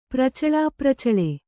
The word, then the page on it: ಪ್ರಚಲಾಪ್ರಚಲೆ: kannada